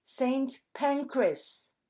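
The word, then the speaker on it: St Pancras: In English, ça se prononce Saint Pancras.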